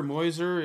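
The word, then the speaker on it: Meuser: Pronounce his last name Mauser. – Muser. Yeah. – Dan Muser.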